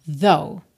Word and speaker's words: though: Though, então o finalzinho ali é mudo, tá? Aquele GH do final some. Though, tá?